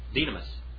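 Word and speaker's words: dynamis (δύναμις): And the German Greek grammars will say dunamis. And you'll hear so many people say dunamis. It's not dunamis, it's dynamis.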